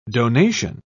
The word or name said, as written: donation